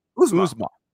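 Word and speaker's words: Uzomah: And then isn't it Usma? How do you say it? – Usma.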